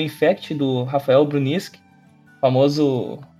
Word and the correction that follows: Brusnik: É o Brunisk ou o Brusnik? – Brusnik. – É o Brusnik. – Rafael, eu falei errado. Bom, se falei errado, agora já corrigiu.